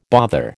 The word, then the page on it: bother: American English